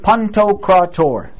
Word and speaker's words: Pantocrator: In the church, we use the word pantocrator. You guys have heard that word before, right? No one knows what it means. Pantocrator. Pantocrator. Four syllables, right?